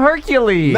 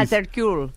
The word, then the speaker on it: Hercule: It's spelled like Hercules. – Call him Hercules. – No, it's not Hercules. – It's Hercule.